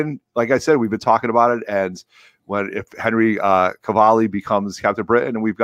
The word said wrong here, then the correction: Cavill: Did you say Cavalli? – Cavalli. How do you say it? – Henry Cavill? – Cavill. – You add an extra syllable.